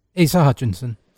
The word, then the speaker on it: Asa Hutchinson: Asa Hutchinson. Did I say that right? – Asa Hutchinson.